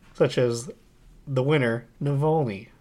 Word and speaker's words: Navalny: Oh, that's actually how it's pronounced as well, as Navalny, not Navalny.